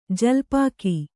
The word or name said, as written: ಜಲ್ಪಾಕ